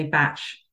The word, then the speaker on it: Bach: Yeah, I pronounce it as back flower. Some people do say Batch,